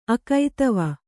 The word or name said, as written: ಅಕೈತವ